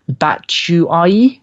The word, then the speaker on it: Batshuayi: Dare I pronounce his name? Batshuayi. Have I pronounced that right, guys? – Nailed it, probably.